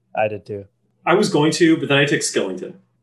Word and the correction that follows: Shillington: How do you say his name? It's Shillington, right? – Sorry, Shillington. I'm sorry.